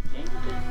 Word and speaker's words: Jingle: Jingle. – Almost rhymes with Winkle.